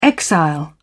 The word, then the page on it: exile: British English